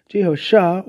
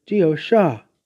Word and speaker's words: Jehosha: Regarding Christ, the nearest name to the Hebrew is Jehoshua, with a convention not readily used in English, or Jehosha, pronounced with or without the H, as in Jehosha or Jehosha. This more complete name of Christ, spelled J-E-H-O-S-H-A, has similarity to God's name.